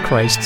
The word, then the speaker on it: Kryst: So it's Christ, K-R-Y-S-T, but it still counts. It's still pronounced Christ.